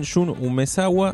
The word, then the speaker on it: Jun Umezawa: De Jun Umezawa. Sí, lo dije bien, Jun Umezawa, así que lo sacó SC Ediciones.